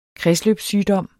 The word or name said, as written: kredsløbssygdom